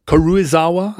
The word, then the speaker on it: Karuizawa: Karuzawa. Karuizawa, I guess, is how it's pronounced.